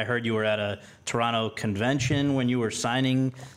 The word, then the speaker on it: Toronto: I was in Toronto, and you know I know about Toronto because I don't pronounce the last T.